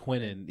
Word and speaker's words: Quinnen: How do you say, is it Keenan Williams? Queenan? – Quinnen, I think. – Quinnen. Yeah, there you go.